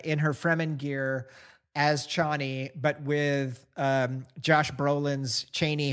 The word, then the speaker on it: Chani: I've heard people pronounce Chaney as Chaney, even though in my head it's a softer ah sound, Chani.